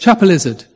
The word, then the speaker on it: Chapelizod: Chapelizod, in Dublin.